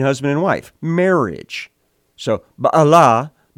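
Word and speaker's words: Baalah (בעולה): The Hebrew word here is typically written out and pronounced as Beulah. But the Hebrew actually says Baalah, Baalah, which, weirdly enough, is a Canaanite word.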